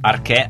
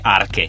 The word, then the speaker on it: Arche: Arche a r c h i senza accento quindi Arche in realtà.